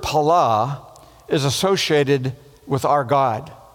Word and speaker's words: pala: And it's the Hebrew word pala, P-A-L-A, pala, pala, accent on the second syllable.